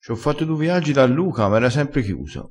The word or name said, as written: fa un viaggio